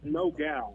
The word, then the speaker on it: Nogal: Well, you guys made the trip out here, and not only did you guys donate a fire truck, the Nogal, and am I saying that right, or is it Nogal? – It's Nogal.